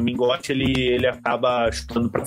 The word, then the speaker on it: Mingote: É, o Minhote, é, Minhote, como é que fala? Minhote, né? Mingote. Mingote.